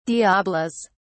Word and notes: diabolos: British English

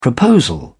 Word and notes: proposal: British English

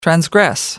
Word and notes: transgress: American English

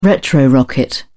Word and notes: retrorocket: British English

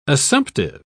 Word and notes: assumptive: British English

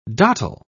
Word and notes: dottel: British English

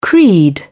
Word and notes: creed: British English

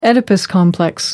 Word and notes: oedipus complex: American English

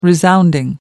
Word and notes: resounding: American English

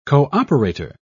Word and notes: cooperator: British English

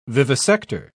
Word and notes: vivisector: British English

